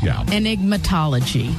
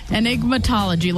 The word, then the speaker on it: enigmatology: Enigmatology, like an enigma.